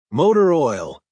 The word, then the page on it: motor oil: American English